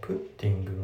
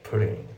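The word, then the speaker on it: putting: でウェアで着てるんだったら、まあ、今回の写真のような状態を表してるんですよね、うん、まあって感じで違うなってなって、であと発音なんだけど、プッティングのその T のところがフラッピングでプリング、もうほぼ L で発音されてますね。プッティングじゃなくてプリングですね。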